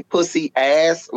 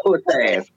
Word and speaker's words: puss-ass: One of the way you know somebody's not from Miami, if they say pussy-ass like that, they ain't from Miami. – Not that. We're not using that many syllables. We say puss-ass. It's puss-ass. It's not pussy-ass.